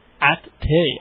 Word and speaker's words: Ati: It's Ati, as in at coffee? – At tea.